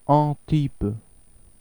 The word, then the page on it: Antibes: ɑ̃tib